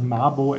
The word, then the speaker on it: Mabo: Und ähm, ja, dann war witzig, es gab mal also so einen, einen Aborigine, der in Australien sehr viel gemacht hat, und der heißt Mabo, also von der Aussprache her, also Mabo, M-A-B-O.